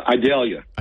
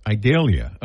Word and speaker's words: Idalia: How do you say it? Uh, Idalia. Idalia.